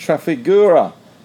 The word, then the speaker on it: Trafigura: And Trafigura, that's how you pronounce it, faces hit of up to $577 million over alleged nickel fraud.